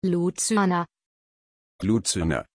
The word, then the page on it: Lucyana: German